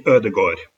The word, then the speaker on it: Ødegaard: From the Norwegian newspaper Verdens Gang, and we want to talk to you particularly about the loan signing of I'm going to say Martin Ødegaard. How is that in terms of pronunciation? – That's quite right. It's Martin Ødegaard.